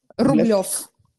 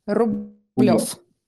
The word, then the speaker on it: Rublev: How do you say his surname in Russia correctly? – Rublev. Rublev. Rublev.